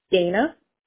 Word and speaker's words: Dana: Dana. – And how do you spell it? – D-A-N-A.